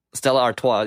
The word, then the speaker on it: Stella Artois: Is it Artois? – It's something like that. I don't know how to say it. – Stella Artois, yes. So it sounds like it's right.